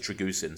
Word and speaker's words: Dragusin: That's because everyone on podcasts was saying it wrong. Now we know it's, you know, pronounced as Dragusin.